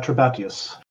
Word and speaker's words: Trebatius: What the others don't know, but Trebatius? Is that how we're pronouncing that? – Yeah, Trebatius.